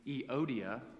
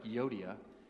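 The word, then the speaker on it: Euodia: It's Eodia, which looks like Yodia, Eodia, and Syntyche.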